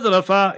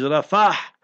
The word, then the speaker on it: Rafah: So it's not Rafa, it's Rafah. R-A-F-A-H. So that is proper pronunciation.